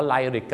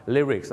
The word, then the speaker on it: lyrics: ไลริกกันเต็มไปหมดเลยเนื้อร้องต้องเป็นลิริกส์นั่นเองครับถึงแม้ว่าหนังนี้ออกมานานแล้วบ้านเราก็ยังออกเสียงว่าไลริกกันเยอะนะครับดังนั้นครับลิริกส์